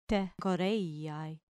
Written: te choreiai

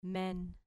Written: men

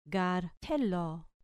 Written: gar thelo

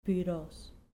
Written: puros

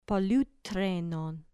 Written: poluthrenon

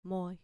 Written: moi

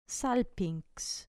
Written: salpinx

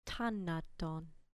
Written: tanaton

